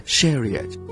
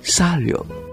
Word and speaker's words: Chariot: 其中最值得注意嘅就是他的首 1962 年灌录的 Chariot，法语的发音呢好像是 Sario，他的这首歌得到金唱片。